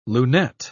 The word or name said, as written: lunette